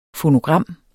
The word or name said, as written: fonogram